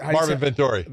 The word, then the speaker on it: Vettori: Vettori? – Don't agree, huh? – Who? No. I do. What's it? – Marvin Vettori.